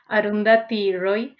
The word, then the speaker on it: Arundhati Roy: There's an Indian journalist and activist called, well, I'm sorry for the pronunciation, Arundhati Roy. – You're correct.